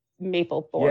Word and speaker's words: Mapplethorpe: Oh, Maple. – Mapplethorpe. – Yeah, yeah. It looks like Mapplethorpe. Because there's two P's.